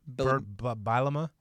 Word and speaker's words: Bielema: Bielamila? Is that how you say it? – Bielema? – Whatever.